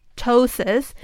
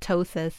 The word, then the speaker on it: ptosis: What is ptosis or ptosis? Like, how do you even pronounce it? – Okay, actually we call it ptosis, okay.